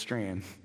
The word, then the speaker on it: Strachan: If you've never heard of him, his last name is spelled like Strachan, but somehow I think his family decided it's Strand.